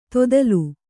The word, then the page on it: ತೊದಲು: todalu